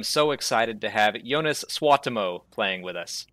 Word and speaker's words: Suotamo: I've been practicing because you said that it was like that coffee noise. That's like the best way you can tell Americans how to pronounce it. – Yeah, that's a small part of it, but it's so difficult. I mean, it's Suotamo, but it's so yeah, that's Suotamo.